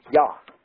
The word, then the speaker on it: Yah: Because, as you all know from always reading of Hebrew names and so forth, they don't have J, they don't pronounce J's, it's Yah. Yah is how they pronounce it.